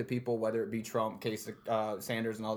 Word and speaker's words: Kasich: Like, that was John, that's Kasich.